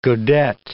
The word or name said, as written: godet